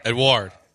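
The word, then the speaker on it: Edouard: It's E-D-O-U-A-R-D. – Edward. – Is it Edward? – Edward. – Edward? I'm going to go with Joe on this one. Edward.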